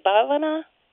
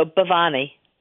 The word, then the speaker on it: Bhavani: do you say Bhavana? – No, Bhavani. – Bhavani. – Bhavani. – Sorry, the H is silent.